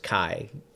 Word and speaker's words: Keye: So that is, I believe it's pronounced Kai. K-E-Y-E.